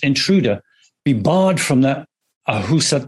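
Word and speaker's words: Ahousaht: Am I pronouncing that right? – Ahusat, yeah.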